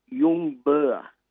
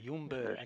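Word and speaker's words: Ljungberg: You drop the L, and the last bit, Berg, is actually pronounced Burr, not Berg. – Okay, Yunberg.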